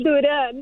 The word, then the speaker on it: Duran: Elvis Duran. – It's Duran.